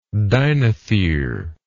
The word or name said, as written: dinothere